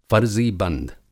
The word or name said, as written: फ़रज़ीबंद